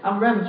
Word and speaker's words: Avram: 原文是 Avram 就是那个亚伯兰，那亚伯拉罕叫 Avraham 啊，就多了一个 H 这个发音。